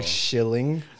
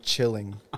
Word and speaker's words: Chilling: I literally thought that's how you pronounce it until I heard it back and you were saying chilling.